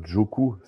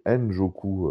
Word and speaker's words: Njoku: Ouais, parce que c'est pas Joku, c'est N-Joku, euh, mon Guigui.